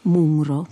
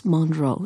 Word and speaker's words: Munro: L'ho scoperto molto tardi perché ho continuato a chiamarla dentro di me Munro per tutto questo tempo, ma so che si dice Alice Munro, esattamente come la grande attrice.